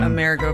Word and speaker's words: Amerigo: A-M-E-R-I-G-E, meaning land of Amerigo, or how do you pronounce his name? – Amerigo Vespucci.